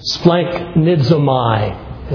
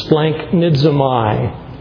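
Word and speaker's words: Splanknidzomai (σπλαγχνίζομαι): Splanknidzomai is how you say it, kind of a mouthful. Splanknidzomai is from a word referring to a man's inner organs, such as his heart, his spleen, or even his intestines.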